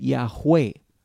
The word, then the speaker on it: Yahweh: and we sometimes pronounce it Yahweh. Or in, in English, we sometimes say Jehovah, but Jewish people will never pronounce this name.